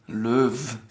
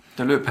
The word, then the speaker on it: Löw: Dann haben wir auch gelernt, dass ich einen leichten Sprachfehler habe, wenn ich das Wort Löw ausspreche, was eigentlich Joachim Löw. – Löw. – Sag mal richtig. – Joachim Löw. – Löw. Ich sag Löw.